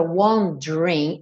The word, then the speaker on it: wondering: Só lembrando nessa né, palavra wondering, o é do meio não é pronunciado. Então não diga wondering, wondering, dream, dream.